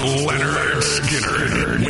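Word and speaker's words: Lynyrd Skynyrd: It's pronounced Leonard Skinner with Hairball tonight.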